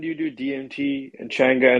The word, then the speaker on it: Changa: This was your last experience with, how do you say it again? Chikanga? – Changa, yeah.